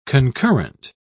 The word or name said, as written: concurrent